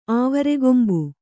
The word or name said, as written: ಆವರೆಗೊಂಬು